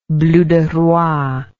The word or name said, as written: bleuderoi